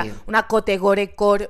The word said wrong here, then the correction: cottagecore: ¿cómo se dice eso? – Cottagecore. – Cottagecore, yes.